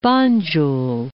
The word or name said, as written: Banjul